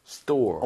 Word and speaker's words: store: 이거 발음할 때 희한한 게 뭐냐면은 store보다 약간 된소리가 나요. T가. – 그게 멋있더라고요. 원래 발음이 네. store 말고 그거 어떻게 해보실래요? – 저 한번 비춰주세요. store. – 오. – store. – 오. 미국인인 줄 알았습니다. 네. store. 약간 된소리. ST 뒤에 모음이 오면 보통 그런 경우가 많아요.